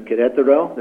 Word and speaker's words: Querétaro: Querétaro.